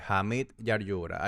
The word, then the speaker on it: Hamid Yaryura: Eh, Hamid Yaryura, H-A-M-I-D, Y-A-R-Y-U-R-A.